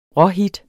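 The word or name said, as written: råhit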